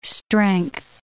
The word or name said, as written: strength